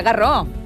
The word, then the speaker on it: Garros: Però es pronuncia Garros.